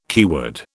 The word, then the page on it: keyword: British English